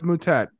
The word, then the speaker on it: Moutet: I'm like 90% sure that his name is probably pronounced Moutet. – No, it's Moutet.